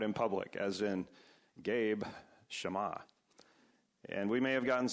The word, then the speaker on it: Shema: It's transliterated, it's spelled Shema, but actually it's pronounced with the two short A sounds.